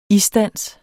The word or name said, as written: isdans